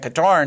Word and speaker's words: Qatar: How do you pronounce that? – Qatar. I think it's pronounced Qatar. I was calling it Qatar until our director, Travis McMillan, went and corrected me on that.